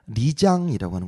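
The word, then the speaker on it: 리장이라고 (丽江): 그런 다음에 또 바로 인근에 있는 버스로 한 3시간이면 가는 곳인데 리장이라고 하는 곳이 리장.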